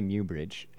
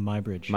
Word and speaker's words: Muybridge: Going back to, however you pronounce his name, I say Muybridge. How do you pronounce it? – I call him My Bridge. – My Bridge. At any rate, there's all those vowels in the first part of his name, so it's kind of...